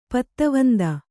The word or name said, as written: ಪತ್ತವಂದ